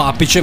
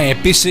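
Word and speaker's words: Appice: Eh, Noi in italiano lo pronunciamo apice, ma lui ovviamente si chiama Epis in inglese.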